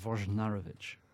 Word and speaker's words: Wojnarowicz: I was told two pronunciations, Wojnarowicz or Wojnarowicz. – I tried to announce this program, I think I called him Wojnarowicz, but so it's Wojnarowicz. So the first J is a Woj then, Wojnarowicz.